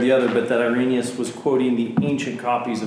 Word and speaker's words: Irenaeus: Irenaeus, he's using it almost the opposite of what, not Sproul, but whoever he was quoting, Gentry.